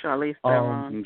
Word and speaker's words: Charlize Theron: Charlie's their own, or whatever, however you pronounce her name. – Oh, Charlize Theron.